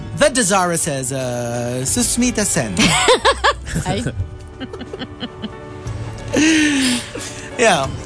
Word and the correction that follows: Sushmita Sen: It's hard to pronounce her name, huh? Susmita Sen. – Susmita Sen. – Sushmita. – Ah, ganun ba? – Sushmita Sen. – Ah, ganun ba? – I have a problem with... – That's Chico feigning innocence. – I have a problem with the SH.